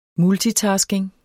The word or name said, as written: multitasking